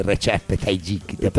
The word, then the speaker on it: Recep Tayyip: I pronostici sono difficili, ma dal presidente turco Recep Tayyip Erdogan, non sapevo neanche che si chiamasse, ma si legge Recep Tayyip, non stanno arrivando segnali di un cambio di rotta in economia.